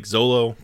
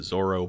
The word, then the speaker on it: Zolo: I know some people don't like Zolo, but like pronouncing his name Zolo as opposed to Zoro, but the manga writes his name that way. This is a podcast about the manga. I will be pronouncing the name Zolo. If you don't like it, I'm sorry. I watched a video online from someone who speaks Japanese and say, they said both are correct, so I don't want to hear about it.